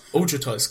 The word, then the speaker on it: Ojutai: Ojutai's Command.